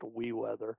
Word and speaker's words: WeeWX: WeWeather, the program itself is WEEWX, pronounced WeWeather.